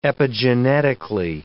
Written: epigenetically

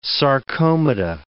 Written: sarcomata

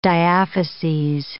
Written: diaphyses

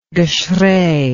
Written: geschrei

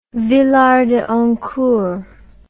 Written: villard de honnecourt

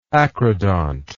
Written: acrodontism

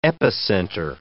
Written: epicenter

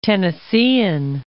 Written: Tennesseean

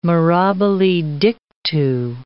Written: mirabile dictu

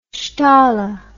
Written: steier

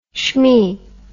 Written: shmee

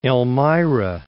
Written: Elmira

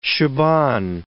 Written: Shaban

